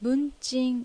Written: Bunchin

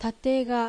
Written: Tatega

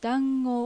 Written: Dango

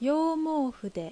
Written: Youmoufu

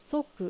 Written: Soku

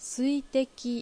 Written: Suiteki

